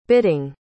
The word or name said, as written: bitting